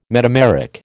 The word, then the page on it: metameric: American English